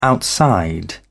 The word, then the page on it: outside: British English